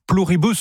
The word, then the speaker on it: Pluribus: Pluribus.